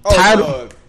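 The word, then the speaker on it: Tidal: How you say it? – With a T-U-D-I? – It's T-I-D-A-L. – Title, that's a jigger shit.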